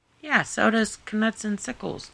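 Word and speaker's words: Knuts: Canuts. He's in the audiobook. He says canuts. That's what it is.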